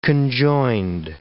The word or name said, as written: conjoined